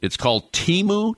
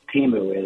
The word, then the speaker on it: Temu: and it's called Temu, T-E-M-U. Is that how you pronounce it? – I think that's how you pronounce it, Flash, yeah.